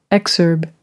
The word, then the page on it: exurb: British English